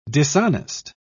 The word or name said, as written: dishonest